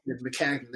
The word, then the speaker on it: Maccagnan: Every everybody pretty much uh, um, Mechanic um, Hanahan, where the fuck his name is, I can never pronounce his last Mc- name. Maccagnan. – Maccagnan, there it is.